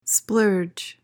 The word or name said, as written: splurge